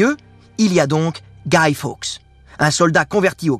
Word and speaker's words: Guy: Alors, Guy, ça s'écrit comme Guy de Maupassant, mais ça se prononce Guy, comme Guy Ritchie.